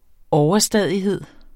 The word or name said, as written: OVERSTADIGHED